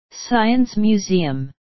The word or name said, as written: science museum